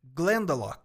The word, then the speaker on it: Glendalough: Glendalough. Glenda, tipo o nome Glenda, que a gente uhum. conhece aqui. E esse lock no final, L-O-C-H. Pronuncia lock.